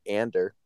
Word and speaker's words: Andor: So I guess let's just talking about Andor then, or Ander. That's how they pronounce it.